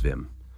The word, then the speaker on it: Wim: It's spelled like Wim, but he's Dutch. Mm-hmm. It's Wim.